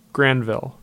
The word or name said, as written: Granville